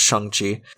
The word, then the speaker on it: Shang-Chi: He's attached to Shang-Chi, which apparently we've been saying that wrong. Apparently it's pronounced Shang-Chi.